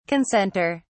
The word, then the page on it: concentre: British English